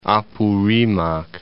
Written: Apurimac

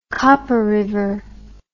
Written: copper river

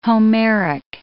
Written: Homeric

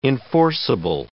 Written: enforceable

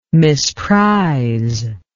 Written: misprise

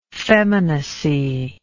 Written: feminacy

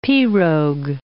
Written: pirogue

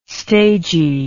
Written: stagey

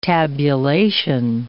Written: tabulation

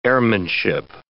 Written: airmanship

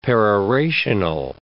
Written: perorational

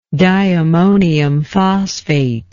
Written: diammonium phosphate

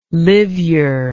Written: liveyer

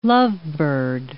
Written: lovebird

